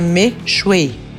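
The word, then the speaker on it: Mixue: Meishui se pronuncia Meishui e já tem 45 mil lojas e superou gigantes como McDonald's, Starbucks e tem cerca de 45 mil lojas.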